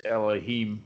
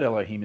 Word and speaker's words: Elohim: But Elohim in the case of Yahweh is kind of special because the word Elohim is actually plural. Okay, that's weird, isn't it? You can tell it's plural because it's got the little I-M on the end, which we pronounce as im,